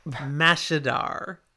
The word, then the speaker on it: Mashadar: Madashar. – That's not how it's pronounced. – I said it wrong. I mean, look at the spelling. – Yep. – Mashadar. That's what I meant.